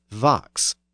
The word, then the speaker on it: vox: The other notable sound change in the first line is the pronunciation of fox as vox. The word is actually spelled V O X. There we see the voicing of that initial sound, so that the F became a V.